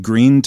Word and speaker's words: Gruene: Down in Greene, Texas. Now, Greene is spelled G-R-U-E-N-E because it's a German community.